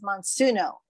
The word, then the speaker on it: Monsuno: Monsuno. Am I saying that right? – Yes, yes.